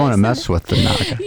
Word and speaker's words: Naga: Naga, as they say. – Naga, right? Is that what they say? – Yeah, that's how you say it.